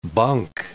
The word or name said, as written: bunk